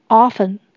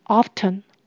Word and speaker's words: often: Often 本身是指时常，请留意这边的 t 可发音也可以不发音，不发音的话就是念成 often，如果发音的话就是念成 often，两种都可以。